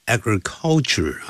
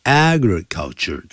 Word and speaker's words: agriculture: 还有 agriculture, 不要念成 agriculture 啊，agriculture 重音在第一音节。